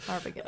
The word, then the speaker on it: Arbogast: Like, the shot of Arbogast, I can't say his name right, Arbogast. – Arbogast.